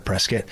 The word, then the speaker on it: Prescott: Yeah, because it's like, you look at the spelling of it, it looks like Prescott. And it's not spelled like biscuit. So there's no UIT literally, or even a U. So a little bone to pick with the town of Prescott.